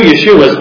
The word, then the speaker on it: Yeshua: So Jesus is the Greek counterpart to the Hebrew Yeshua, meaning God is salvation. Yeshua, commonly pronounced in English as Joshua.